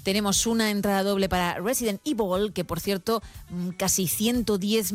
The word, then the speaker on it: Resident Evil: Creo que se están riendo los compañeros de que digo Resident Evil. Me da igual, es cómo se pronuncia. Es verdad que aquí hemos dicho siempre Resident Evil.